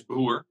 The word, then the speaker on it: Broer: And my last name is Broer, which means brother. But in Dutch, my last name Broer is pronounced in other languages.